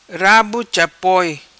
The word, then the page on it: Rabo Chapoi: patua